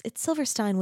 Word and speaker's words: Silverstein: His violins have been played on and praised by Joshua Bell, Joseph Silverstein, I think it's Silverstein when the I is second, right? You pronounce the second letter.